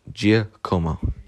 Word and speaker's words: Giacomo: Giacomo. Okay, it's not Giacomo. It's not Giacomo. It's Gia. Comb. Oh, three syllables. Right. Giacomo. It's Italian for James.